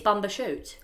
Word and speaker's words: Bumbershoot: Can you spell it for me? B-U-M-B-E-R-S-H-O-O-T. Mm. It is Bamba shoot. Bamba shoot.